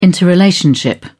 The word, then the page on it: interrelationship: British English